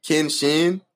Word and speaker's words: Kenshin: It's spelled Kenshin, but this nigga name is Kenshi.